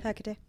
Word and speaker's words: Hecate: We do run into a few other ones, including Hecate. I'm pronouncing that right? – Yep. Hecate.